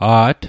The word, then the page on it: a't: Mi'gmaq